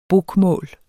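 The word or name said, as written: BOKMÅL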